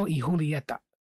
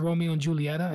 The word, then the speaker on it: Romeo y Julieta: Romeo y Julieta. People pronounce it Romeo and Julieta. It's actually Romeo and Julieta. Y Julieta.